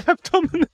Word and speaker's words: abdomen: Abdominum. – Again, you really struggle with that one, don't you? You really want to throw in another syllable. – Abdomen? I can't say abdomen. Abdomen.